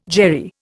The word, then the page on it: Jewry: Indian